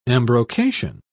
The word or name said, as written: embrocation